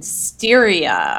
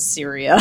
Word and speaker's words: Styria: I thought that Laura, I'm just looking at amid a forest in Styria. Oh, I probably pronounced it Syria.